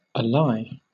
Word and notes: ally: British English

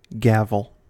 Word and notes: gavel: American English

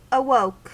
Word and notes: awoke: American English

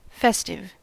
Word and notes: festive: American English

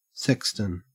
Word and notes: sexton: Australian English

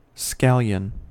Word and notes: scallion: American English

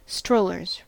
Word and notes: strollers: American English

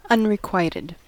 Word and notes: unrequited: American English

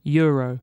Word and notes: euro: British English

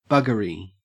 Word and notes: buggery: Australian English

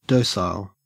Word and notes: docile: Australian English

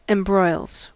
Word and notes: embroils: American English

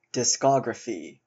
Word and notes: discography: American English

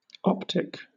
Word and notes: optic: British English

